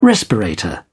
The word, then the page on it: respirator: British English